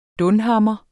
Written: dunhammer

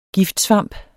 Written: giftsvamp

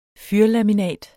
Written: fyrlaminat

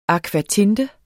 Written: akvatinte

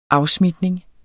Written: afsmitning

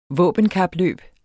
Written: våbenkapløb

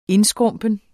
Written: INDSKRUMPENT

